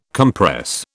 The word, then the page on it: compress: British English